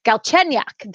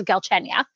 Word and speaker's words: Galchenyuk: So Galchenyuk, that is how it is pronounced. I have figured this out because last game they were moving the emphasis around each syllable of his last name, but it is Galchenyuk.